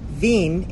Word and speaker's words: Wien: Vienna is pronounced Wien in German, so who can say for sure just who can lay claim to being the source?